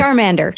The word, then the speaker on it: Charmander: Charmander? Is that how you say it? – Charmander.